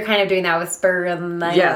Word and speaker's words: Spirulina: No, how do you say it? – Spirulina. – Spirulina? – Yeah. – It's not Spirulinella.